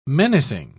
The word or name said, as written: menacing